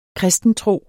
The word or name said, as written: kristentro